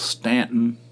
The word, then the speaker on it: Staunton: It was spelled Staunton, pronounced by the locals Staunton.